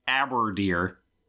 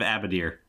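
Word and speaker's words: Abadeer: Some demons mispronounce the Lord of Evil's last name, saying Aberdeer. – Weird. – Instead of Abadir.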